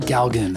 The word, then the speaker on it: Galgan: Galgan.